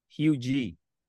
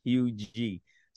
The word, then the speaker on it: Huger: My last name is pronounced Hugie. So my mom is from South Carolina and dad from South Carolina, and our last name is pronounced Hugie.